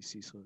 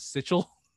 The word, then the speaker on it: Cecil: At work, I thought of the time when we were filming the other episode and you mentioned like Angelo pronounced Cecil as Sitchel.